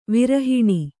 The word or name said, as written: ವಿರಹಿಣಿ